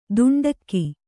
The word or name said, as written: ದುಂಡಕ್ಕಿ